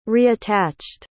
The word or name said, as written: reattached